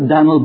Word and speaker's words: Dan'l: The Hebrew word Daniel has three syllables, Dan-iel. The English word, two, Daniel. But the American in those days, only one. Have you ever heard of Dan'l Boone? Have you ever heard of Dan'l Webster? That's how they spelled it, D-A-N apostrophe L, and they pronounced it as one syllable.